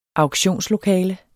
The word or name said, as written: auktionslokale